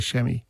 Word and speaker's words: chamois: It is, and it's spelled chamois, but we say chamois.